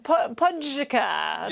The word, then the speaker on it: Pogačar: 22-year-old Tadej Pogačar, as I like to pronounce it. – How would you pronounce that? – Pogačar. – Yes.